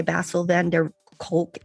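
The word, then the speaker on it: Bessel van der Kolk: You said that one of your favorite quotes is by Basil van der Kolk, MD.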